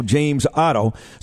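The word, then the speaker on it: James Oddo: It's spelled O-D-D-O, not O-T-T-O. James Otto.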